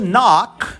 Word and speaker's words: knock: Pois o verbo bater em inglês, to knock, bater numa porta, por exemplo, a gente escreve K-N-O-C-K, mas a gente não lê a letra K porque ela é outra silent letter. A gente lê a partir da letra N, knock.